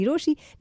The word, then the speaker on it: Rochie: मेरा नाम है मिर्ची रोशी. नाम की स्पेलिंग है ROCHIE.